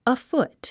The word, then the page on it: afoot: əfút; アフト